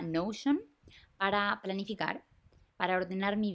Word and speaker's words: Notion: así que se llama Notion, o en español o sea, si la pronunciara como español, Notion, como N-O-T-I-O-N.